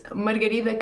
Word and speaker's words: Margarida: Okay, Margarida, I pronounce your name with my Brazilian accent from João Pessoa, Margarida Carvalho. I think it would be nice for the listeners to hear the pronunciation with your Portuguese accent. – It is Margarida Carvalho.